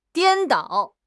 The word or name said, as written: 颠倒